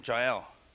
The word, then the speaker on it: Jael: Some might say Jael, but don't say it like that, guys. All right? Because it's Jah, Jehovah, El. Two words for God, banded together. What a beautiful name, Jael.